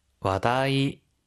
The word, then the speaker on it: 話題: 와다이, 와다이. – 화제라고 하는 한자어 음으로 그대로 있습니다. 그래서 와다이.